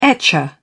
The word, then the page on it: etcher: British English